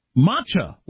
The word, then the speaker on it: matcha: When you were trying to pronounce matcha, it's pronounced matcha, like gotcha.